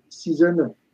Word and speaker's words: Season: Vous savez pas quoi faire, vous avez assez écouté de foot, vous pouvez aller écouter le podcast Season 1, Season, comme en anglais ça se prononce, S-E-A-S-O-N et One comme One.